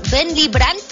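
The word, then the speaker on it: Ben Liebrand: Y Lee Brand, ¿quién es Ben Lee Brand? Bueno, pues Ben Lee Brand, así es como se pronuncia.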